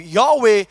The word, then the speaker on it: Yahweh: Yahweh, if you pronounce it right, according to the original Hebrew language, you shouldn't use your tongue. You shouldn't use your tongue, all right? There's no tongue in it.